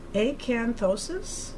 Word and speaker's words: acanthosis: Dr. Bill, a skin scalp punch biopsy, acanthosis, am I saying that right? – Right.